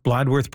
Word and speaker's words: Bladworth: Am I pronouncing Bladworth properly, Greg? – Yes, you are.